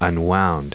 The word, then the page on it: unwound: un*wound; American English